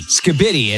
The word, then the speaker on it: skibidi: Skibidi. It's S-K-I-B-I-D-I. Skibidi. What does that mean? We'll start with you. – Isn't it pronounced skibidi? – Skibidi. Yeah, here you go.